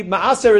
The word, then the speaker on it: maaser: How do you pronounce the noun correctly? – Maaser. – Same thing. – This is me'aser. Me'aser would be the verb.